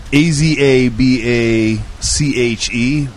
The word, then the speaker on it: Azabache: This is a CLE, uh, and I'm probably going to pronounce this wrong, but Azabash. Azabash. I like that. A Z A B A C H E, maybe?